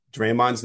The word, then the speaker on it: Draymond: They had Iguodala talking about Draymond, and so I started to panic, thinking, "Oh my God, have I been mispronouncing Draymond's name wrong?" But I think that was just how Andre likes to pronounce it. I think Draymond will say that the accent's in the second part of the name.